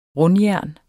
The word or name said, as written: rundjern